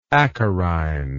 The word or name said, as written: acarine